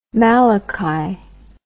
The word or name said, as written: malachy